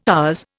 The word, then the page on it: ours: Indian